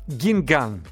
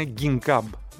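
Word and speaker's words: Guingamp: Λοιπόν, παίζουν στη Γκίνγκαν, έτσι, αυτοί που λένε Γκίνγκαμπ, αλλά δεν προφέρεται έτσι.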